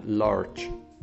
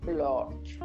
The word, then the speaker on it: large: Como porque la G no es como la G, es como la CH en inglés, ¿ok? Es, sí, sé, lo es raro, pero es, suena large, large, ese se pronuncia large.